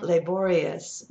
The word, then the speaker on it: laborious: So this is where your British English and R in American English, because the R in British English often sounds like us, laborious, but we need to hear that R. So laborers, laborers, laborious.